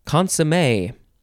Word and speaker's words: consommé: It is the word consommé. C-O-N-S-O-M-M-E. There is an accent on the E, down on the left and up on the right.